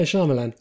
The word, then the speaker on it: Shyamalan: How do you pronounce it? – Shyamalan. – Oh, I always thought it was Shyamalan. – No, Shyamalan.